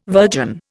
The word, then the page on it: virgin: Indian